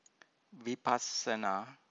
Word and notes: vipassana: Pali

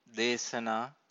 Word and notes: desana: Pali

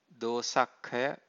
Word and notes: dosakkhaya: Pali

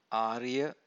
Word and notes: ariya: Pali

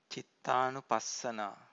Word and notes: cittanupassana: Pali

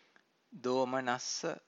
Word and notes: domanassa: Pali

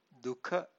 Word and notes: dukha: Pali

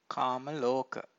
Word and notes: kamaloka: Pali

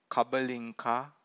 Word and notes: kabalinka: Pali

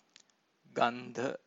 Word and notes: gandha: Pali

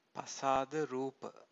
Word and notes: pasada-rupa: Pali

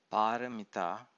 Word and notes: paramita: Pali

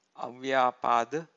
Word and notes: avyapada: Pali

